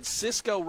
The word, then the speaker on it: Sisko: Because I said Sisko rhymes with Crisco.